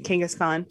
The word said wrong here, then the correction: Genghis Khan: And I think actually it's Genghis Khan.